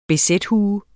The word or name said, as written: bz-hue